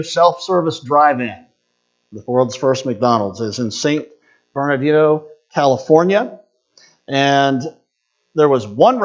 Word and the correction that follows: San Bernardino: San Bernardino, I'll say it right.